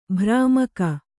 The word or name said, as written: ಭ್ರಾಮಕ